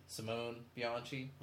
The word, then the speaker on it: Simone Bianchi: With the black and white covers done by Simon Bocelli, but I don't know how to pronounce it. Simone Bianchi. – Mm-hmm. – There we go.